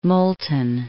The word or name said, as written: Moulton